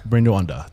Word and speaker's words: Buena Onda: Bueno Onda, am I saying it correctly? – Bueno Onda. – Bueno Onda. Thank you.